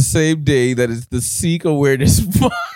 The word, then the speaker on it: Sikh: It's pronounced Sikh, by the way.